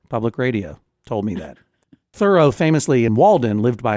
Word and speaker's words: Thoreau: Joel Mann, did you know that Thoreau is pronounced Thoreau? – No, I did not know that, Judge.